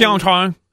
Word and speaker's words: Pyeongchang: How do you pronounce the Winter Olympics in what town Pyeongchang. – Are they in? – Pyeongchang.